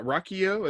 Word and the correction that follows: Rocio: It's R O C I O. How do you say that? – Since you're mm, Ro- since you, Rocio sounds pretty good to me.